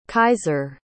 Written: kaiser

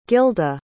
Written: gilda

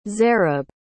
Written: zareb

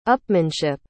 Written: upmanship

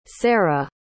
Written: Serah

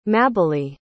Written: mabili

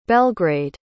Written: belgrade

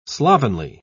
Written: slovenly

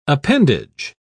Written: appendage

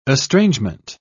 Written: estrangement

